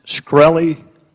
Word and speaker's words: Shkreli: Pronounced Shkreli? – Yes, sir.